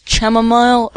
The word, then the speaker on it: chamomile: Now, some people pronounce this chamomile, others chamomile. For the purpose of this, I'm going to pronounce it chamomile.